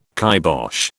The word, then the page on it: kibosh: British English